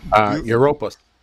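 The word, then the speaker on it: Dura-Europos: Near the site of the ancient synagogue at Dura Europus? How do you say that? Dura Europus. – Europus, okay.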